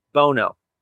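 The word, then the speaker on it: Buono: So it was a little bit of work, but it is Cara Bono is how it's pronounced. So it's the U is silent, apparently.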